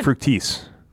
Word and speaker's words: Fructis: The only cosmetics he uses regularly are a face lotion in the mornings and Garnier Fructis. Did I pronounce that right? – Mm-hmm, you did. – Fructis.